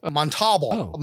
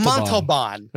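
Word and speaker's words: Montalbán: as Montalbo, Montalban, which hey, it's Montalban. Montalban. – It's Montalban. Right? – Like, come on. Like, what? Come on. Montalban. I was like, what is this? – Anyone pronounce it that way? – Anyone worth their Corinthian leather knows Montalban. – Right?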